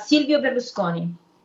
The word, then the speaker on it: Silvio Berlusconi: Silvio Berlusconi.